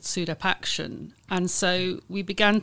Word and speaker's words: SUDEP: So I just want to introduce an acronym into the conversation that Beverly mentioned briefly in episode one, which is SUDEP, which is spelled S-U-D-E-P.